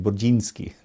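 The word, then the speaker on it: Burzynski: Actually, in Polish, you would say his name Burdzinski, but that's how I always thought it.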